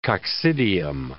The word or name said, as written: coccidium